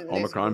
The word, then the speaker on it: Omicron: So it's getting a little scary with this Omicron. Am I saying it right? – Yeah, Omicron Greek, variant.